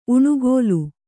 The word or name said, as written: ಉಣುಗೋಲ್